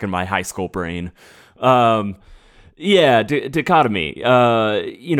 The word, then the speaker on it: dichotomy: Dictamy? – What? – The one that starts with the D I C H O. – Oh, dichotomy? – Oh, thank you.